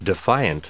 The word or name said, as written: defiant